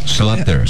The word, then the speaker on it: Schlatter: Como que se pronuncia esse sobrenome dele? – Schlatter. – Schlatter. – Como? – Schlatter. – É isso mesmo.